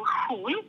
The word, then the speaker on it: Xun: Other instruments came from China, like, for example, Hun, you spell it like X-U-N.